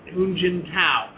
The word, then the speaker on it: Hu Jintao: and it was showing Chinese President Hu Jintao arriving here.